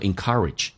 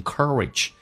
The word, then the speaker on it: encourage: 首先啊 keep on 我们这持续的不断的鼓励我， encouraging 这个单词啊，美式发音跟英式发音还有很大的差别，英式发音叫做 encourage，美式发音 encourage。